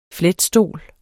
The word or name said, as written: fletstol